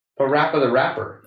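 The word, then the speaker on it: PaRappa the Rapper: How are you pronouncing that? – Pa Rapa the Rapa? How do you pronounce it? – A Rapper the Rapper. – Oh, okay. – Because he's a rapper.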